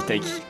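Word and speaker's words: Tayc: Même ça s'écrit T-A-Y-K. – On dit Taïki.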